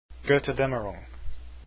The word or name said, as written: goetterdaemmerung